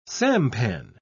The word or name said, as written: sampan